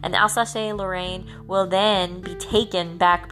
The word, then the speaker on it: Alsace-Lorraine: After the Franco-Prussian War, which is the war between France and Germany, or Prussia, we see the loss of Alsace-Lorraine. Alsace-Lorraine. That's how you pronounce it.